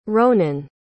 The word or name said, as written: ronen